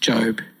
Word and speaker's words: Job: In Job chapter 38, I know it initially looks like Job, but it is pronounced Job.